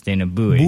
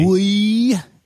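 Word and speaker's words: Dana Buoy: Because it looked kind of, I know he said some people pronounce it as Dana Bowie. – Boy.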